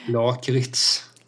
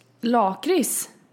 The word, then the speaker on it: lakrits: Lakrits. – Lakrits? – Lakrits. – vadå, varför lägger du in ett T? Det är tyst T. – Det stavas ju lakrits.